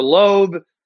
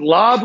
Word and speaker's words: Lobb: It's either Lobb or Loeb. Is it Lobb like a pitch, or is it Loeb like the singer, Lisa Loeb? – No, it's like the pitch. – It's like the pitch, Lobb.